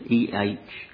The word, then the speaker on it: Ehud: Enhud, Ehud, and Shamgar, Shamgar.